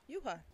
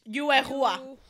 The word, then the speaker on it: Yuehua: Also, this week, we found out that Woods is finally leaving Yuehua. How do we say this? Yuehua? – Yuehua. – Me reading in Spanish. Yuehua. – That one honestly sounds better.